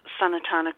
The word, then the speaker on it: Sanatana: But in the Sanskrit text, our first four are Sanat Kamora, Sanatana Kamora, Sanadana Kamora, and Sanat Kamora. I'm not sure I'm pronouncing the second one correctly. Sanatana. Sanatana Kamora. There we go.